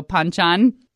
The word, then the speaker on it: banchan: So it's with a P, even though it's spelled with a B. So Banchan.